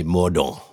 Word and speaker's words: Mordaunt: Penny Mordaunt. That's how we pronounce Mordaunt.